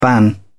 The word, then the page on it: ban: British English